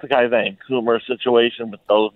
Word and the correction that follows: Cuomo: Oh yeah, Cuomo.